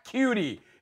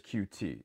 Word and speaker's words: QT: And I was like, he's not cutie. It was bothering me so badly. And he did it every single time. And I know they have a pronunciation guide. I know they do. And they kept calling him cutie. It's like, no, it's Kiki QT, like the two letters QT.